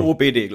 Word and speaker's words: GoBD: dieses GDOB, ich kann sie mir nicht richtig aussprechen, Grundsätze der ordentlichen Buchführung. – GOBD, glaube ich. – GOBD, genau, so, vielen Dank.